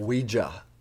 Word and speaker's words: Ouija: Oh, but there is like, he explained to them that it's not a Ouija board, it's a Ouija board. – Ouija. That's how it's pronounced.